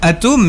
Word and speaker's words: Atoem: Alors on prononce Atom, mais ça s'écrit A-T-O-E-M.